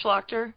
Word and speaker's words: Schlachter: And yeah, Schlachter, close.